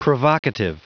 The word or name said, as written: provocative